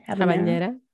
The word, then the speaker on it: habanera: So the costume, she was humming the habanera. Is that how you say it? – Habanera.